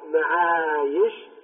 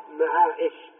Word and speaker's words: معايش: إذن هذه لا تقلب همزة في الجمع، وإنما ينطق بها ياء فيقال معايش، كما قال الله جل وعلا: وجعلنا لكم فيها معايش. ولا يصح أن تقرأ معايش، لا يصح أن تقرأ بالهمزة.